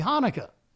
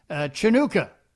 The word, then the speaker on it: Hanukkah: Somebody asked him to make a cameo video wishing someone a happy Hanukkah. But whoever requested it used the C-H spelling of Hanukkah because Smokey, who apparently had never seen that before, pronounced it Chinooka.